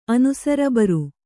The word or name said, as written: ಅನುಸರಬರು